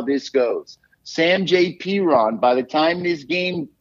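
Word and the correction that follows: Samaje Perine: Maybe this Samjay Perine, P-E-R-I-N-E. How do I know how to pronounce his name? Because he's a Jets castoff.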